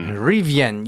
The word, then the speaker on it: Rivian: Rivian, ja, das ist jetzt plötzlich der zweitwertvollste Autobauer der USA, ist mehr wert als BMW.